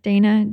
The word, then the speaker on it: Dana: Instagram under my name again, like Dana James. – Easy. Yes, I know. How? So for everybody listening, her name is Donna, but it's spelled like Dana.